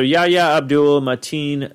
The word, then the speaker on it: Yahya Abdul-Mateen: I believe it's Yahya Abdul-Mateen II. – Yes. So Yahya Abdul-Mateen II, he actually was in a movie.